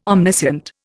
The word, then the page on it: omniscient: Indian